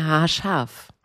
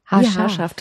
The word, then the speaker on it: Haarscharf: Monja Haarscharf. Haarscharf. Wie haarscharf dran vorbei. So wird es ausgesprochen.